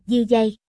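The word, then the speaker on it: Yudhi: Tên anh là Yuhi, phát âm là Yuhi.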